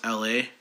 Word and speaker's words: Elhae: E L H A E. That's how you spell it. It's an acronym for every life has an ending. But it's pronounced L.A. Like Los Angeles, L.A.